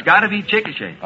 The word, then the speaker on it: Chickasha: C-H-I-C-K-A-S-H-A. I said Chickasha. – Oh, Mr. McNeil. – That is not right, I've no, taken. No, no. But the name is pronounced Chickasha.